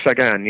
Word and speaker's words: Sagan: Have you figured out what the correct Slovak pronunciation is? It's Sagan, I would imagine. – I think it's Sagan, yes.